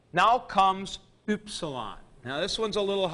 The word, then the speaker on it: upsilon: We get it in words like hymn, H-Y-M-N. And that upsilon is pronounced with that umlaut, U, U. It's upsilon, U.